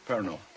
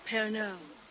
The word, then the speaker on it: Pernod: Pernod. The D is silent. You're going to hang around with the Fallons. You better get it right, kid. – Pernod.